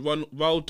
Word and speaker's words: Roald: Anyway, the final bit of word on the street was about, she's gonna tell me off for saying it wrong, but it's Ronald Dahl. – Roald Dahl, everybody. Roald Dahl. – Ronald Dahl. – Why are you saying Ronald? It's not fucking Ronald. Ronald McDonald.